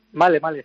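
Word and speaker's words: Malé: ¿Male, cómo se dice? – Sí, Male, sí.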